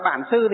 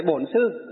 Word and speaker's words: bổn sư: Ngoài Bắc chúng ta đọc là bản sư, thì trong hòa thượng toàn dịch là gì? Bổn sư.